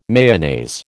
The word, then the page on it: Mayonnaise: American English